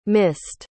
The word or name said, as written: missed